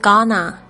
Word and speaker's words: gonna: 这个 going to 将要做什么事的时候，常常在这个口语里面呢，就是会说成 gonna gonna，拼的时候呢是 g o n n a gonna。